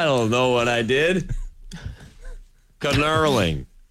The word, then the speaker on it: knurling: 800 people have let me know it's pronounced knurling. The K is silent.